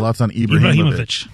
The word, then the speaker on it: Ibrahimovic: But the media, Zlatan, how do you say his last name? – Zlatan Ibrahimovic. – Thank you so much.